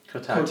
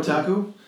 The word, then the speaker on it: Kotaku: How do you say that word? – Kotaku. – Kotaku.